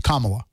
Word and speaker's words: Kamala: He's going to want to know why I didn't correct you for pronouncing it incorrectly. It's you. It's Kamala.